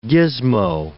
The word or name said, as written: gismo